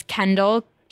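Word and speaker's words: Kendyl: So it's Kendall, K-E-N-D-Y-L-L. – Two L's. – My name is spelled with one L, actually, but my last name is Lynch.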